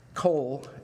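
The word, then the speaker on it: kol (קול): But anyway, it's basically kol is how you say it.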